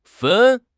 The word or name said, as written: fen1